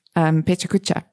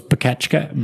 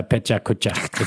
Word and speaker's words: Pecha Kucha: For a while, people thought it was Pekachka, but it does seem to be Pecha Kucha. I watched a video of Japanese people saying it, and it's a Pecha Kucha.